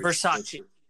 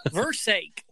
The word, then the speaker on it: Versace: Or Versace, or however Versace. – However it's supposed to be pronounced. I know there's a very particular inflection that you're supposed to do, and I can't do it.